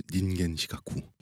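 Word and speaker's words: 닌겐 시가쿠 (人間失格): 근데 인간 실격을 일본어 원어로 발음하면 닌겐 시가쿠.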